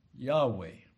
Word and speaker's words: Yahweh: God's name Jehovah is his name, which is written simply in these capital letters, YHVH, which is pronounced Yahweh. Yahweh.